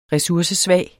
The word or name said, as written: resursesvag